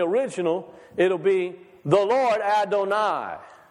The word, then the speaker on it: Adonai: The Lord, Adonai. It's easy to remember it because it rhymes with El Shaddai.